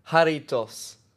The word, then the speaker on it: Jarritos: And it's not J in Spain. It's H. Jarritos.